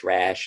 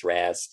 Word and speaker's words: Ra's: But I've heard it as Ra's and I've heard it as Ra's.